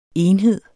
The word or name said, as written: enhed